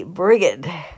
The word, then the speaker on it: Brigid: Her name, which means exalted and fiery, is actually pronounced with a hard G, so it should be Brigid, though I can't really say that.